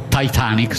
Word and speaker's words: Titanic: Siamo sul Titanic o Titanic, se volete la pronuncia più anglofona.